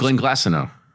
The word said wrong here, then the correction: Glenglassaugh: Yeah, Glenglassaugh.